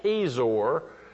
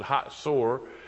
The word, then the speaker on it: Hazor: They pronounce it Hatzor, but it is spelled in English. If you pronounce it English, it's Hazor, but the Jewish people pronounce it Hatzor, and it's the biggest tell in Israel, and there is Hazor right there.